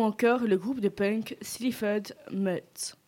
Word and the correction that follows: Mods: Mutt, je ne sais pas comment on prononce. – Comment ça s'écrit ? – S-L-E-A-F-O-R-D-M-O-D-S. – Mods, ouais, du coup, Mods.